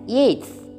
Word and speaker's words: Yeats: You have to pronounce it as Yeats.